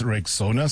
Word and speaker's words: Rexona: It is pronounced Rexona. Simple, strong, and easy to remember.